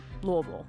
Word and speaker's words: Louisville: I'm from a small town outside of Louisville, and that is exactly how you pronounce it, Louisville.